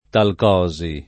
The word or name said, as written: talcosi